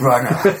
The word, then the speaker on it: Brunner: I pronounce it Brunner.